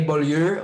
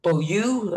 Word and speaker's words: Beaulieu: The French way, Beaulieu, or uh, Beaulieu, uh, the English way to say it.